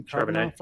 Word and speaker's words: Charbonnet: If you look at, you know, Zach Charbonnet, is that how I pronounce his name? – Charbonnet. – From Charbonnet, thank you.